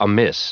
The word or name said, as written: amiss